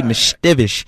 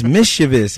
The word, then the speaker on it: mischievous: I just say mischievous. And they was like, no, it's mischievous.